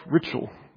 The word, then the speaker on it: Ritschl: but his name was Albrecht Ritschl. Ritschl is one of those eight-letter German words that has one vowel in it, so it's a little difficult to pronounce.